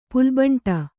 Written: ಪುಲ್ಬಂಟ

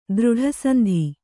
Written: ದೃಢಸಂಧಿ

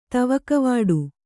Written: ತವಗವಿಡು

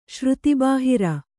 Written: ಶ್ರುತಿಬಾಹಿರ

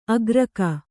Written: ಆಕರಿಕೆ

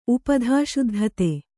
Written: ಉಪಧಾಶುದ್ಧತೆ

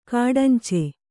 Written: ಗಡಂಚಿ

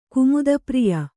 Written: ಕುಮುದಪ್ರಿಯ

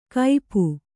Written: ಕೈಪು

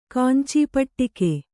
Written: ಕಾಂಚೀಪಟ್ಟಿಕೆ

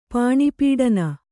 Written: ಪಾಣಿಪೀಡನ

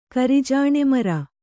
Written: ಕರಿಜಾಣೆಮರ

